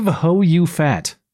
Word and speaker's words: Houphouet: But while we wait, now there is a French basketball player named Steve Houphouet, and that is how it's pronounced.